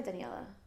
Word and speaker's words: Daniela: Had people call me Denise because they don't Denise. Understand how to say it, I guess. Mm. And I'm like, you know what? Just call me Daniela. I can't do that. I'm not a Denise.